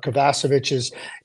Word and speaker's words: Kovacevic: You know, my apologies. I said Kovacevic. You know, it changes, but Kovacevic is the pronunciation.